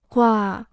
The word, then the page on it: 挂: guà